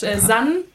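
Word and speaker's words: San (زن): Kommen wir zum dritten Lied, das du ausgewählt hast, und zwar Rana Mansur mit Zan. Habe ich es richtig ausgesprochen? Welche Sprache ist das? – Ja, das ist eben iranisch, San.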